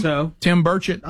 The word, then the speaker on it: Burchett: And one of the most troubling ones comes from, I'm going to tell you what, I thought Burchett was, is it Burchett? Say his name? – I thought Tim Burchett. – That's what I thought.